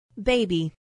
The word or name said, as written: baby